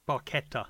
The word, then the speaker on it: Barchetta: So certainly, I would say that something like a little Barchetta, have I pronounced that right? – Barchetta.